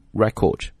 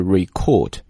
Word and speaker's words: record: 我们有的时候经常可以用这个词叫 praised，或者是 highly praised highly，对吧，叫高度赞扬了，和 outstanding record，他的这种非常杰出的啊，之前的这样的政治的履历，他的这样的记录， record 表示履历记录。注意一下这个词，这个词呢，如果我们把它读成了动词，就是 record，它的重音在 cord 上面。